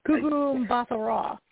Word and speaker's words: Gugu Mbatha-Raw: Her last name is difficult for my American tongue to pronounce. Gugu Mbatha-Raw. That's how you say it.